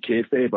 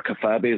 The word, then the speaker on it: kayfabe: I read the inside burns, and it told me you got to keep kayfabe, or kafabe as I pronounced it at the time, because I was 11 year old.